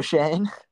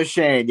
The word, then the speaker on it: Schoen: I guess shout out, shout out Joe, shout out Joe what what how do you pronounce his last name? The Giants GM? Joe Schoen. Joe Schoen, yeah.